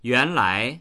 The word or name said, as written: yuan2lai2